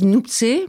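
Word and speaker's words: Nuptse: Alors, je ne sais pas du tout si je prononce bien Noopse. – Eux disent Noopse, mais Noopse, d'accord. – On va dire que c'est Noopse. Donc, c'est le nom d'un des sommets de l'Himalaya.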